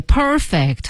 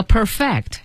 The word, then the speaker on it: perfect: 对，perfect 和 perfect 是不同的发音了。